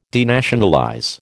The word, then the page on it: denationalize: American English